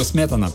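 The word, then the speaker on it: Smetana: Там, правда, ударение на другую букву – Сметана.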